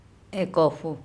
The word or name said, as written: EkOpHo